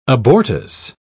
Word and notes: abortus: British English

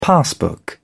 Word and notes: passbook: British English

